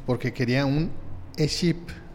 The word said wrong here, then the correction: eSIM: ¿Sí se dice así? ESIM un ESIM, un ESIM.